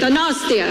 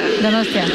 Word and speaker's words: Donostia: Donostia. – Donostia. – Donostia.